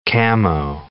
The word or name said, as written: camo